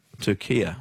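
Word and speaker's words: Türkiye: But in yesterday's Socceroos updates, you kept saying Turkey and not... – Turkia. – What's doing? I never heard of Turkia, Ryan, is my honest answer. – Yeah. – Never, ever, ever heard of it pronounced that way.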